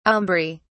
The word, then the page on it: aumbry: British English